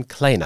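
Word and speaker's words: Kleene: Stephen Kleina.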